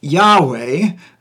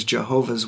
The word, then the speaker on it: Yahweh: now i pronounce it yahweh um, some people might say it's yahweh other people if you if you've read like an old King James version you might have heard the the word Jehovah uh, certainly if you're familiar with the group of people known as Jehovah's Witnesses they pronounce it Jehovah um,